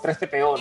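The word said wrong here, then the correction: C-3PO: C3PO. – Tres CPO, sí, siempre lo digo mal.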